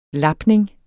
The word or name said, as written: lapning